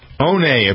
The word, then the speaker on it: One: One, apparently, is how you pronounce this guy's first name, even though it's spelled like one.